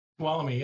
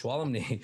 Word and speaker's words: Tuolumne: How do you say that? Tuolumne? – Tuolumne, yes. – Tuolumne.